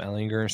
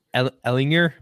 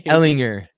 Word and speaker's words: Ehlinger: And with Sam Ehlinger still being the quarterback in Indianapolis. Or Ehlinger? My bad. – Thank you. You guys need to say his name. – Ehlinger.